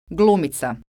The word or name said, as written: glumica